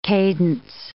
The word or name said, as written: cadence